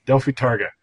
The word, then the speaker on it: Delphi Tage: Then we have, and I'm going to call it Delphi Days, but what's the German word for it? – Delphi Tage. – All right, that's in Berlin. – Yep. – And I probably still said it wrong.